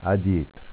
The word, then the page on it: Adietra: dialetto